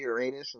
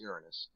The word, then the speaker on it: Uranus: So people, when they say Uranus, I'm like, oh, come on, man. That's not how you pronounce it. It's Uranus.